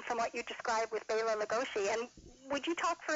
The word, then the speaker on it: Bela Lugosi: Very often people would pronounce it as Bela Lugosi on radio and in movies, and even today it's mispronounced, but that's the correct pronunciation of his name. And very often if you hear him at the beginning of a program, when he's introducing himself, he will definitely put the accent on Bela.